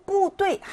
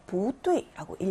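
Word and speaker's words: 부뚜이라고 (不对): 그리고 뿌뚜이 하지 않고 뚜이가 사성이기 때문에 부뚜이라고 읽어줘야 맞다.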